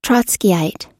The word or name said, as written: trotskyite